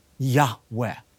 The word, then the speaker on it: Yahweh: Now, the Lord, Yud He Vav He, oftentimes pronounced with such reverence, a breathy Yahweh.